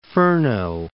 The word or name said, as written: Furneaux